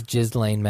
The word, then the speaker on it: Ghislaine: It's Ghislaine Maxwell. – I've been calling her Ghislaine because I thought that was real. – Yeah, everyone reads it and thinks that's how it's pronounced.